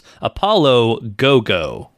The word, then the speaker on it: Apollo 55: It's written as Apollo 55, but apparently it is pronounced Apollo Go-Go, which Go is Japanese for 5, which if we were still numbering our episodes, as a fun fact, this would be episode 55.